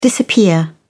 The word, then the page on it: disappear: British English